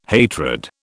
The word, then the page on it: hatred: British English